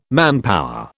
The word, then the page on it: manpower: British English